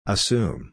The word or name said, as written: assume